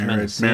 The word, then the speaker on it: Mendocino: Is it Mendocino? Like pepperoncini. – I think it's Mendocino. Maraschino cherry.